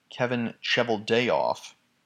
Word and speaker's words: Kevin Cheveldayoff: We'll talk a little more about that later, but the actual, the 2010 alleged assault, regarding that, then general manager, and I believe I'm pronouncing this correctly, Kevin Cheveldayoff,